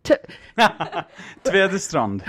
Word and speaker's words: Tvedestrand: Tvedestrand.